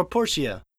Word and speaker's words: Portia: My time in Portia or Portia? – Portia, because there's voice acting in it, and they pronounce it Portia.